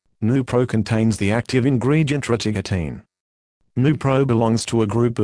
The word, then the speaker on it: rotigotine: Rotigotine, pronounced Rotigotine.